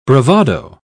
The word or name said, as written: bravado